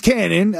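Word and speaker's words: Kannon: My name's Cannon, it's spelled with a K, you probably heard of me.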